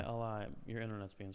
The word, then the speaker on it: Jai alai: Oh, it's pronounced Hai Alai.